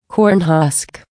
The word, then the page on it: corn husk: American English